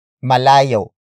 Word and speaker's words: malayo: For example, malayo pa ako. Malayo pa ako. Malayo pa ako. It means I am still far away. Word for word, it's malayo pa ako. Malayo pa ako.